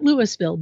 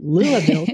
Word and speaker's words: Louisville: You notice I say that correctly, not Louisville, but Louisville, Kentucky, right.